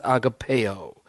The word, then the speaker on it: agape: And then there's the word agape, or some people pronounce it agapeo.